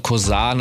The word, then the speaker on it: Kozarth: Und ihr könnt die Musik von Cosar natürlich da auschecken, wo ihr eure Musik konsumiert. Das wird ein bisschen anders geschrieben als gesprochen. K-O-Z-A-R-T-H. Also ausgesprochen mit einem härteren S und Z lautmäßig. Und Junes wird geschrieben J-U-N-Z.